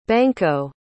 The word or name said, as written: banco